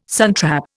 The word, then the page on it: suntrap: Indian